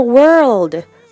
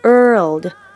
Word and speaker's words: world: とかちょっとトーンに気をつけて考えてみましょう 2 つほど考えてみませんかさてこの It's a small world この world word とちょっと発音が違いますが R の後に L って舌を引っ込めた後に舌を前にって出して world world ね。